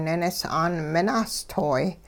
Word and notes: ninis'aan minahstoy: Hupa

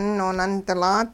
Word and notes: noonundilaat: Hupa

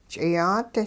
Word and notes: ch'iyaat: hupa

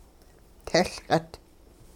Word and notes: tehLqit: Hupa

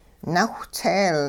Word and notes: nawhtseel: hupa

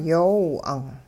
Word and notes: yoow 'ang': hupa

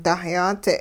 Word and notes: dahyaadze': hupa

